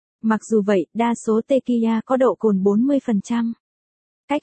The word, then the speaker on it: tequila: Tequila, người Mỹ phát âm là tequila,